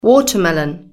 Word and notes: watermelon: British English

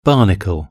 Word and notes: barnacle: British English

